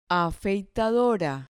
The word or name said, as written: afeitadora